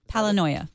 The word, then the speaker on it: Palinoia: Palinoia. – Paranoia with an L.